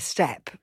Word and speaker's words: Steppe: So, as I say, teachers, you know, I remember The Endless Steppe.